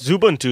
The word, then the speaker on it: Xubuntu: The MacBook is from 2006, and I've gotten some more life out of it by installing Xubuntu, or is that how you say it? – Zubuntu is how I say it. – Zubuntu, on it a couple years back.